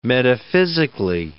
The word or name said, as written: metaphysically